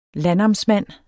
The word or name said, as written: landnamsmand